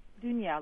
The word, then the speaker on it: Dunya: Dunya, like sand dune with a on the end.